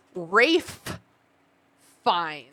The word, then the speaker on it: Ralph Fiennes: It sounds like she's saying Ralph Fiennes, but it says... The spelling of how you should pronounce it is Rafe Fiennes.